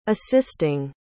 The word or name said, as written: assisting